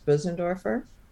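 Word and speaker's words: Bösendorfer: tell us a bit about the, and I hope I pronounce this correctly, the is it the Bosendorfer? – Well, that's, piano? That's close. It's the actual German pronunciation is Bosendorfer.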